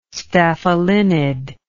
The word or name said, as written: staphylinid